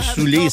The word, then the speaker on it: Sule: Soulé, c'est S-U-L-E. OK? On le dit à l'anglaise, ouais. Mais ça s'écrit Sul. C'est son premier album.